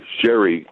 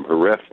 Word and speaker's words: Jerez: You know, the Brits are great for, in fact, the industry, sherry really gets its name from Jerez de la Frontera, H-E-R-E-Z. And the Brits pronounce that sherry. And so thus the name sherry.